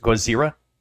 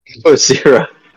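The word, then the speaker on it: Gozira (ゴジラ): Uh, you know how like um here in America, Godzilla is Godzilla, but in Japan it's pronounced Gozira. Gozira.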